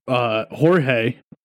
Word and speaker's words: Jorge: There's only one name that I can think of that we do that with, and it drives me fucking bananas, and it's fucking Jorge. When it's spelled J-O-R-G-E, and it should be Jorge, because if it was George, it would be G-E-O-R-G-E.